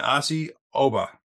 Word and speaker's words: Asi Oba: Today, with me, I have Don Pemberton, DJ Pemberton from FRC, and Asi Oba. Did I say that right? I want to make sure I said that right. Uh, yes, sir.